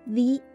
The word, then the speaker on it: the: 这里的 the 同样的要读成 the 因为 ocean 海洋是以元音开头的